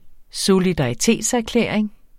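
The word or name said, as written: solidaritetserklæring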